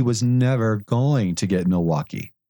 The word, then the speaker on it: Milwaukee: She kept saying Milwaukee, and I said, no, Milwaukee. – Really? – Just put the accent on the wa. Milwaukee.